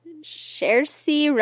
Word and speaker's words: Saoirse: Can you pronounce her name? – Saoirse Ronan. – Perfect.